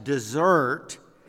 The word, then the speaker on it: desert: Hundreds of years ago, this English word desert, also pronounced dessert, meant more than a piece of pumpkin pie.